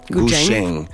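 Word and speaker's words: Guzheng: Ça se prononce Gujeng. Ouais, Gujeng. C'est plus un CH, un TCH, mettons.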